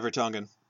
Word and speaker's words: Vertonghen: Case in point being that, and I'm going to mispronounce his name, is it Vertonghen? – Yeah, Vertonghen. – In the second half,